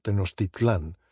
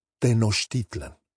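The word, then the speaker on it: Tenoxtitlan: Note, for example, that in the novel, the stress in Tenochtitlan falls not on the A, as it would in Spanish, Tenochtitlan, but on the I, and the word is spelled with an X, Tenochtitlan. It's more or less the name as it would sound in Nahua instead of Spanish or English, Tenochtitlan.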